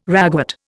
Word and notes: ragwort: Indian

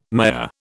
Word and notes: Maya: British English

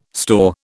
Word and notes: store: British English

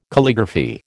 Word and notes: Calligraphy: American English

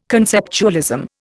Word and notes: conceptualism: Indian

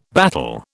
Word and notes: battle: British English

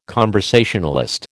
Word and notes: conversationalist: American English

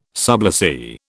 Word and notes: sublessee: British English

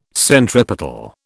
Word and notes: centripetal: British English

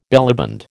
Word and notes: bellyband: American English

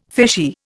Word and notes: fishy: Indian

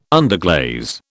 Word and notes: underglaze: British English

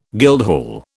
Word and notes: guildhall: British English

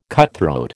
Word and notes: cutthroat: American English

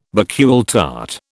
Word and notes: Bakewell tart: British English

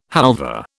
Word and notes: halva: British English

